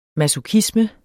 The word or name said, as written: masochisme